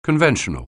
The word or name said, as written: conventional